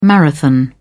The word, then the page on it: marathon: British English